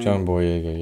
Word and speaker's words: Boyega: John Boyega, yeah.